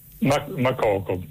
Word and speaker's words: Makoko: Makoko, non so se è con l'accento finale alla francese, Makoko, però probabilmente siccome quello è un paese anglofono sarà in maniera diversa.